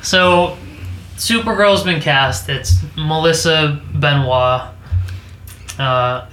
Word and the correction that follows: Benoist: Yes, it's pronounced Benoist, but it is French.